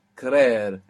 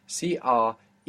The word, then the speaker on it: creer: So, to believe is creer, creer, and it has two E's, CREER, creer.